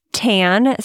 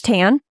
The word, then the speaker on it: Tann: On Instagram, it's Tan. So our last name is Tan, but it's spelled with two N's. So T-A-N-N, Kidney Kid.